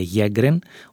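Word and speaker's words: Sjögren: Και μια και το αναφέρουμε, γιατί και εμείς δεν γνωρίζουμε πολλά για αυτό, το σύνδρομο Σέγκρεν πήρε το όνομά του από τον Σουηδό οφθαλμίατρο Χένρινγκ Σέγκρεν, στα σουηδικά προφέρεται Γέγκρεν,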